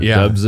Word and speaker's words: Dubs: Dubs is my last name. DBS. That is pretty awesome. – People always spell it wrong too. They'll put two B's in it.